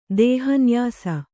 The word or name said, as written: ದೇಹನ್ಯಾಸ